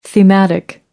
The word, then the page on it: thematic: American English